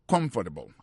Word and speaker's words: comfortable: I don't feel comfortable. Alors, on va sentir confortable ou en créole ou du sac, justement confortable comme ça. Mais en anglais, you appuyez sur premier syllabe.